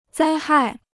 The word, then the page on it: 灾害: zāi hài